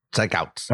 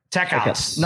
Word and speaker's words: Tekautz: Tekauts? T-E-K-A-U-T-Z. How would you say that? T-E-K-A-U-T-Z. – Sounds right. Tekauts. – All right. Tekauts.